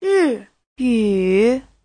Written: ri4yu3

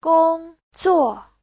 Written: gong1zuo4

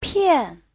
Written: pian4

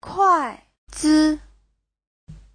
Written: kuai4zi5

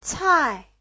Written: cai4

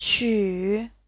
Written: qu3

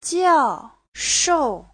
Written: jiao4shou4

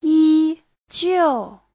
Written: yi1jiu4

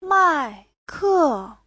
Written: mai4ke4

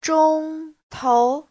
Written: zhong1tou2

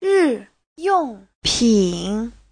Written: ri4yong4pin3

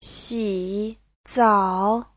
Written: xi3zao3

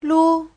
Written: lu5

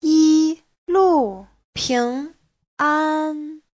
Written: yi1lu4ping2an1

